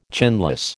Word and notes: chinless: American English

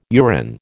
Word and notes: urine: American English

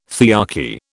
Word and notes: thearchy: British English

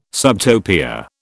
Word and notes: subtopia: British English

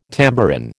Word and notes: tambourin: American English

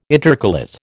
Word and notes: intercalate: American English

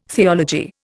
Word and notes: theology: Indian

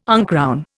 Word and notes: uncrown: Indian

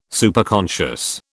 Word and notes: Superconscious: British English

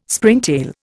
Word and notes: Springtail: Indian

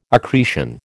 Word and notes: accretion: American English

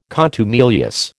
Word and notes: contumelious: American English

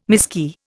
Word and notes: miskey: Indian

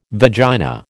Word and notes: vagina: American English

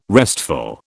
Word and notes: restful: British English

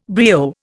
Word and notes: brio: Indian

